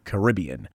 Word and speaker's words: Caribbean: I've want to try Caribbean or Caribbean? – Caribbean. It's, well, you can say it either. Americans say Caribbean, but people from the Caribbean say Caribbean.